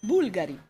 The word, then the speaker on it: Bulgari: So there's one that doesn't have a second to last stress syllable. – Yeah. – Because it's Bulgari.